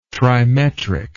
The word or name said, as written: trimetric